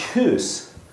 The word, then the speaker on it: Hus: Hus, his last name is Bohemian. It's pronounced Hus, goose. He is a goose. That's his name, means goose.